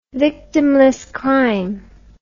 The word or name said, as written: victimless crime